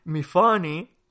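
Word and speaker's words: Myfanwy: Pero bueno, el caso es que, dada, esta explicación en la que él nos dice que rima con Tiffany y que por tanto se pronuncia mi funny, o sea que realmente, como ya he dicho, no rima con Tiffany, eh, entonces, a partir de aquí yo dije, bueno, bien, si tú me estás diciendo que, el énfasis está en la, en la sílaba central, la segunda sílaba,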